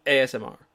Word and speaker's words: ASMR: Right now, tell me your top AMSR. – ASMR. – I'm trying to say it right, but I'm saying it too fast.